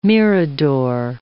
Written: mirador

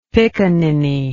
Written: piccaninny

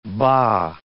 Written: bah

